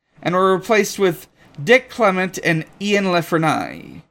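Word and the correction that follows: La Frenais: I mispronounced that. Clement and LeFrain wrote a more action-heavy script, but too over the top, too much budget.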